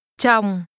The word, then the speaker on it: chồng: Chồng. Chồng. Chồng. – This is pronounced with a falling tone.